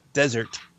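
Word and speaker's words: Désert: We did get to see Alex Desert. Is that how you say his name? – No, Desert? It's not, but it's okay. – Deseret, whatever. – No, the accent's on the E, on the first E, but come on.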